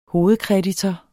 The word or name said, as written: hovedkreditor